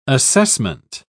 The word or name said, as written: assessment